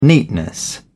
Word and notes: neatness: British English

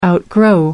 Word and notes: outgrow: American English

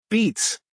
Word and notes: beets: American English

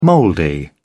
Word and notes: mouldy: British English

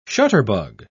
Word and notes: shutterbug: British English

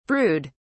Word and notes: brewed: British English

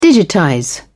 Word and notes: digitize: British English

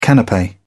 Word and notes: canape: British English

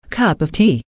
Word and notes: cup of tea: American English

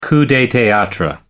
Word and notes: coup de theatre: American English

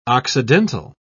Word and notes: occidental: British English

